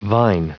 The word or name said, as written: vine